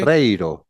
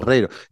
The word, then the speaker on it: Barreiro: Am I saying that correctly? – Barreiro. – Barreiro. – Barreiro. Yeah, I'm going to put you some Spanish R exercises.